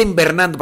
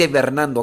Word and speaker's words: hibernando: No se dice invernando, para que no me vengan a querer corregir, se dice hibernando, ¿ok? Porque luego no me falta ahí el que... Estás deseando malo, se dice invernando porque es de invierno, tu abuela. No se dice invernando. Dice Ibernando.